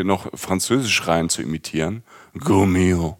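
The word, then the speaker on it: Gault&Millau: Inzwischen hat er, glaube ich, 4 Go-Mio-Hauben. Ich glaube, so spricht man das aus. Also Go-Milau geschrieben, Hauben. Also, Go-Mio. – Go-Mio, genau. Okay.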